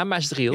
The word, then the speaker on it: Maasdriel: Het was toch Maasdriel, zeg ik dat goed? – Ja, Maasdriel, ja.